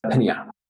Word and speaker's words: Pinia: Pinya. I'll drop a link in the show notes. – It's spelled P-I-N-A, right? – P-I-N-I-A.